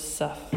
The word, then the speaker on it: sough: or more likely a mispronunciation of the word suff, which is spelled S-O-U-G-H, and defined as a drain or a sucking sound.